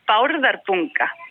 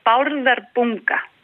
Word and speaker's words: Bárðarbunga: This one is Bárðarbunga. This one is Bárðarbunga. Can you say that? – What's the first letter? – B. – B. Bárðarbunga. – Yeah. – Bárðarbunga.